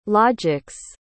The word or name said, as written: logic's